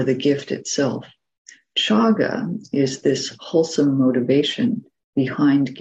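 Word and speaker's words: cāga: The Pali word is chaga. That's spelled C long A G A, chaga. And it has two important and related dimensions. So chaga is sometimes translated as generosity, and that's the first dimension.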